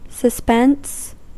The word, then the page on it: suspense: American English; /səˈspɛns/